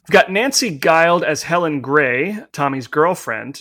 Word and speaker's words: Guild: The publicity team declared, quote, "Guild rhymes with wild," end quote.